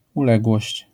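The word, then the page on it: uległość: Polish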